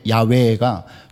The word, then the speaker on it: 야훼: 이거는 이제 여호와를 야훼라고 하잖아요. 그렇죠. 야훼, 야훼가 좀 더 정확한 발음인데 거기에서 따온 이름입니다.